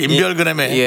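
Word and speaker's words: 인별그램: 1번 그램에 사인지. – 1번 그램이 뭐예요? 1번 그램이. – 오늘 발음이 계속 새네요. 왜 이러지? 인별 그램에 예.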